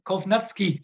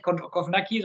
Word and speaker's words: Kownacki: Also wer mir richtig gut gefällt, ist äh, Kovnatski, wird da glaube ich ausgesprochen, ne? nicht Kovnacki, sondern Kovnatski.